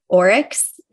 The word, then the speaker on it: Oryx: It was published in Oryx.